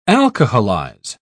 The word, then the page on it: alcoholize: British English